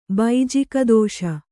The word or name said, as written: ಬೈಜಿಕದೋಷ